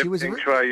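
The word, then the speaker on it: Peng Shuai: It's a fascinating story, and it'll be interesting to see how it unfolds regarding Ping Shui. I think that's how you pronounce her last name. – Yeah, Ping Shui, you're all over.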